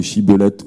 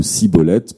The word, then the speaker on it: shibboleth: le prononcer shibboleth ou sibboleth permettait de distinguer les Galadéens des Éphraïmites, et lorsque les mauvais étaient démasqués, ils étaient passés par les armes.